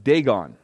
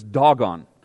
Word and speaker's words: Dagon: Now, growing up, I was always said Dagon, right? You probably heard Dagon. The Hebrew way of pronouncing it is Dagon. That's how come I've been doing that.